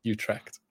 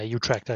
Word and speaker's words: Utrecht: Have I said that right? – Utrecht, is it? – Utrecht. I'm yeah. so bad at foreign pronunciation. It's awful. Hold my hands up to it though. – Yeah, Utrecht. I think that's uh, that's where Vorm came from yeah.